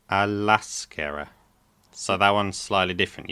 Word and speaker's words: a l'esquerra: You say that as one word, right? – Yeah, l'esquerra. Okay, as you know now, hopefully, you remember the two vowel sounds often combine to make that one word. Okay, so instead of saying a la esquerra, it's just a l'esquerra.